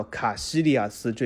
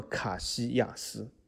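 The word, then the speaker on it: Casillas: 就关于叫药里斯，那么药里斯有个同胞门将叫卡西利亚斯，这也是翻译错误的啊！大家心里面想一下，如果用法王刚才告诉大家药里斯这个读音来翻译卡西利亚斯，它应该叫什么？我给大家一秒钟，对的，它应该叫卡西亚斯啊，卡西亚斯，对吧？因为为什么双 L 发 Y 的音，卡西亚斯。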